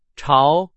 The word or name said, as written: 朝